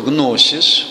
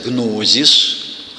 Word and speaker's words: гносис: У нас почему-то стали писать «гнозис». Какой-то дурак один написал, а другие стали повторять. Теперь говорят «гнозис». Никогда в жизни в греческом языке «и» между двумя «с» никогда не читается как «з».